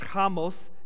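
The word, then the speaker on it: hamos (חמס): And the word oppressed is hamos, actually pronounced hamos, H-A-M-O-S.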